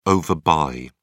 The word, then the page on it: overbuy: British English